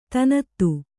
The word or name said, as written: ಧನದ